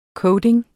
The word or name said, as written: coating